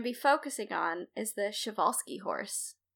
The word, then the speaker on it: Przewalski: Yeah, it's pronounced Przewalski. – Holy my God. Well, I've already learned something, because I always have tried to say the word, like, because it's PRZ. I've always been like, Przewalski. – Przewalski. Yeah, no, that's it. It's pronounced Przewalski. It's a lot of continents in a row.